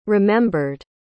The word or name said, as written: remembered